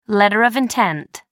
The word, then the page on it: letter of intent: American English